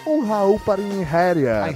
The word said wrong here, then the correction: Einherjer: Não Você falou, é Einherjer. Einherjer.